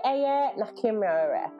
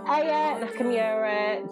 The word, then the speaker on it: Aya Nakamura: It is called Jaja by Aya Nakamura. No, I definitely did that name wrong. Aya Nakamura, Jaja.